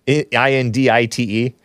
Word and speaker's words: indict: INDITE. It's spelled indict.